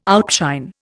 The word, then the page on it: outshine: Indian